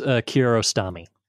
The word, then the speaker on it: Kiarostami: Abbas Kiarostami.